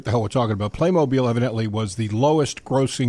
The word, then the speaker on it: Playmobil: And if you've never played with Playmobil, it's pronounced Playmobil.